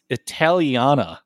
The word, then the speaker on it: Italiana: Condé Nast Traveler and La Cucina Italians. It's Italiana.